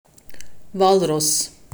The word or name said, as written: Walross